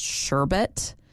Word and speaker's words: sherbet: Like, I didn't know that sherbet was actually spelled and pronounced sherbet, S-H-E-R-B-E-T.